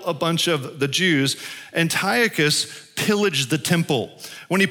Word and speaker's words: Antiochus: Uh, uh, uh, his, his, name is pronounced three or four different ways, but Antiochus is how I'll say it.